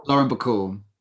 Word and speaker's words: Lauren Bacall: Lauren Bacall? – Lauren Bacall.